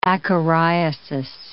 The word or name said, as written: acariasis